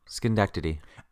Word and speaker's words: Schenectady: And where we see him is he suddenly appears on a street corner in, I'm going to try to pronounce this, Schenectady, New York. – Schenectady.